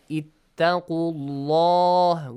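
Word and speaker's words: اتقوا الله: If we have a fatha or a dhamma sound before the word Allah, the letter lam must be pronounced heavily, like ittaqullah with full mouth.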